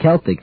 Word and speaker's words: Celtic: If you're in certain areas of the world, but if you look in a dictionary, look in any dictionary, it'll give two pronunciations, Celtic and Celtic.